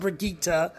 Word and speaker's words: Brigitte: Brigitte, her it's pronounced Brigitte, but it's spelled Bridget.